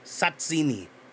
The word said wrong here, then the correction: tzatziki: Tzatziki? – Oh yeah, sorry, tzatziki.